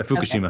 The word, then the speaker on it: Fukushima: is it Fukushima? How do you say That's correct. A Fukushima.